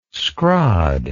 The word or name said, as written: schrod